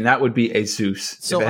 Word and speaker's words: Asus: The official way to pronounce it is Asus. – Wow. – Like Dr. Get Seuss.